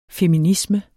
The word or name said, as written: feminisme